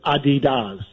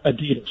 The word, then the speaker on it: Adidas: By the way, is Adidas the European pronunciation? Is that how they say it over there? – Yeah, my German friends, and it's a German company, pronounce it Adidas, not Adidas.